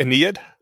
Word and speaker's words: Aeneid: It's pronounced the Aeneid? – I mean, that's how it was pronounced to me in my memory.